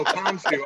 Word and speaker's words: Khonshu: I've been mispronouncing that name for 20 years. And now I'm like, Khonshu, okay.